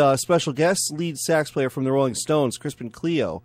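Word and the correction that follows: Cioe: I didn't pronounce that right. It's Crispin Cio.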